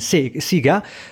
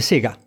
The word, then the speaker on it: Sega: Noi siamo cresciuti con Siga, ma no, se tu provi a dire online Siga, ti arrivano i cazziatoni, perché il mondo dice Sega. – Allora, il mondo dice Sega perché lo pronuncia alla giapponese,